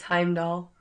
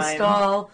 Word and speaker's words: Heimdall: Oh, him stall. – Oh, Oh, man. – It's Heimdall. – I have it written down, too, but I said it wrong, like, every time. Heimdall.